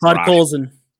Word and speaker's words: Podkolzin: It's Podkolzin.